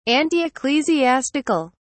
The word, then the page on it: antiecclesiastical: British English